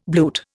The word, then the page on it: bluet: Indian